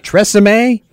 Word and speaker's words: Tresemme: Tresemme? Is this how this thing is pronounced? – That would be Tresemme. – Tresemme? – Yes.